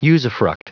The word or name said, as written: usufruct